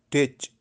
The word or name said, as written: Tech